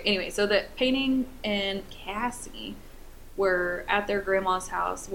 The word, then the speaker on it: Casey: Pamela's older sisters, so Penny and Casey, or sorry, it's pronounced Cassie, spelled Casey, C-A-S-E-Y, but it's pronounced, like, at C-A-S-S-I-E, so I want to say Casey, but it's Cassie.